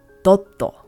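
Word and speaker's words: dotto (ドット): In Japanese, this is pronounced dotto.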